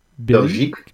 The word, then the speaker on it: Belgique: Belgique.